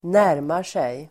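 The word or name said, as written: närmar sig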